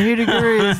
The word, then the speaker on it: Hoodoo Gurus: Up next, we got What's My Scene by Hoodoo and Gurus. – I beg your pardon? – Did I say that right? – No. – Hoodoo Gurus? – Yeah, no, Hoodoo Gurus. There, you got it.